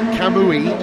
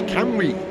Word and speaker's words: Kamui: It's not Kamui, it's Kamui, apparently.